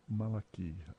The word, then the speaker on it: Malachi: Malachi. – Yeah, you got it. You said it correctly, actually.